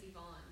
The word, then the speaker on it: Yvonne: My legal name is Yvonne. – Ah. – Nobody can pronounce or spell it. – Is that with Y-V-O-N-N-E? – Yes.